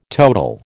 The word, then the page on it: total: American English